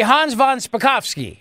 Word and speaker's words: Spakovsky: All right, but first, let me turn my attention to our guest, Hans von Spakovsky. Spakovsky, Hans, am I saying that correctly? Hans von Spakovsky, how are you? – I'm doing fine, and you got it exactly right.